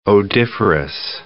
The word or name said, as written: odiferous